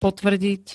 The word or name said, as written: potvrdiť